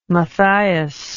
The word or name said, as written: Mathias